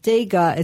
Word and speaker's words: Tega: And it's Dega. It's pronounced like with a D, but it's spelt with a T. – Yep. – So it's Tega, but it's pronounced Dega.